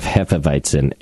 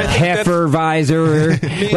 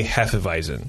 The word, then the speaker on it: Hefeweizen: So it generally doesn't bother me, the mispronunciation, but I will agree that the many pronunciations of Hefeweizen is fucking annoying. – Well, yeah. – Hefeweizer. Like, even if you just did it phonetically, how would you ever come up with Hefeweizer?